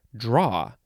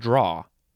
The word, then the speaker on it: drawer: Okay, number two has a different pronunciation in, I guess, certain dialects. I guess they would just pronounce it draw. So no sound at the end, just draw.